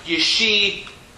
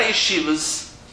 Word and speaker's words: yeshivot: Yeshivas, but that's not how Sephardim call it. They call it yeshivot. Yeshivot. Okay? Not yeshivas, yeshivot. And you'll never hear this letter being pronounced other than T.